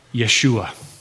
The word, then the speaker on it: Yeshua: The name Joshua would have been pronounced Yeshua. If you saw Joshua and you heard his friends calling out to him, they would say, Yeshua.